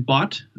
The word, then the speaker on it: Bott: And my name is Brian Bott. That's pronounced robot, like robot. – Okay. – Bott, and I play the bass guitar.